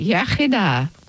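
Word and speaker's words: Iechyd da: You say yachida, good health. – Yachida. – Yeah, the English say yachida, but it's yachida, good health.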